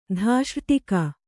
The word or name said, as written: ಧಾಷ್ರ್ಟಿಕ